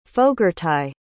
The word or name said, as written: fogartaigh